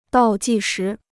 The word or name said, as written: 倒计时